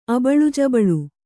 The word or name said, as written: ಅಬಳುಜಬಳು